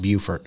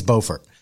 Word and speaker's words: Beaufort: I think that's how you say it, not Beaufort. I think it's Beaufort.